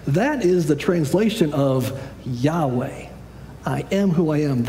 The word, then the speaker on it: Yahweh: We pronounce it Yahweh as sort of our best guess as to how that's pronounced.